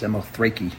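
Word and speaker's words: Samothrace: That's actually how it's pronounced, Samothrake.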